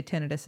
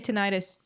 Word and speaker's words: tinnitus: I would, I hate to be judgmental, but yes, I would say tinnitus is the right way to say it. But if you say tinnitus, I'll know what you mean.